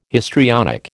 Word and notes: histrionic: American English

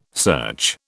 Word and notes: search: British English

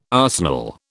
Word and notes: arsenal: British English

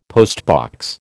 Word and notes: postbox: American English

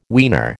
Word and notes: weaner: American English

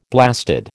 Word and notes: blasted: American English

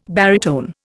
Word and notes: baritone: Indian

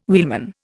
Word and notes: wheelman: Indian

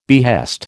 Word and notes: behest: American English

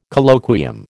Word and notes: Colloquium: American English

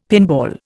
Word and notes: pinball: Indian

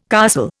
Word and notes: castle: Indian

